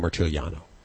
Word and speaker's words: Marciuliano: We have uh, a good friend, Francesco March Marchuli. Yeah. How do you pronounce his last name? I've got a Marciuliano. I really messed it up last time, so I'm gonna I'm gonna practice before he gets on the air. Francesco Marciuliano.